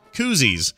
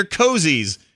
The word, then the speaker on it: koozie: After a short argument of which is right, cozy or koozie, they took a vote of the audience, and overwhelming majority of the people in the room agreed that those little beer foam thing holder cup deals are in fact pronounced koozies. – There you go. – Well, it says here, what are you people, Canadian? They're cozies. Cozies.